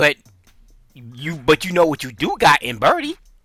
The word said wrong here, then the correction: Purdy: Or Purdy, I keep on saying his name wrong.